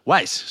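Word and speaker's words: YS NET: Sí, sí, lo nuevo de WiseNet. No, Is. – Wise, se pronuncia.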